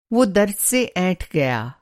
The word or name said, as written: ऐंठना